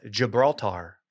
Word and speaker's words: Gibraltar: Killer whales learn to sink yachts, Will, off Gibraltar.